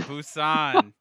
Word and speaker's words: Busan: So hang out with us as we watch Train to Bo-teen. I said that wrong. – Or Busan. – Busan.